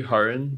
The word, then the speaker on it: Harun: Yeah, like it's actually Harun, but like I don't know.